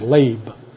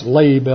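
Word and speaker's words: lab (לב): The Hebrew word for heart is L-E-B. And in most languages other than English, E usually has an A sound, so it's pronounced lab. It's spelled L-E-B in Hebrew. It's pronounced lab, L-A-B-E.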